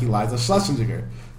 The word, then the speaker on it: Eliza Schlesinger: That's why I don't like Eliza Schlesinger, whatever her name is.